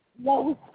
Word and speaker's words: Lois: Lois Jeffrey.